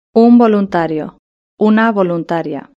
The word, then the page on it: un voluntario/una voluntaria: Spain Spanish